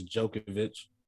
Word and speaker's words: Djokovic: We got Novak Djokovic, whatever I say his name. I think it's Djokovic.